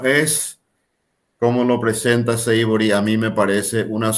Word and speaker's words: Savory: Savory.